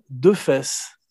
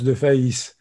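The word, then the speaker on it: Defays: Parlons un peu, si tu veux bien, de Pierre Richard, Maurice, Charles, Léopold, De Defesse. Oui, enfin, ça se prononce de Faïs. Ah, de Faïs, pardon, parce qu'il aurait dû garder son vrai nom, c'est plus drôle que Pierre Richard.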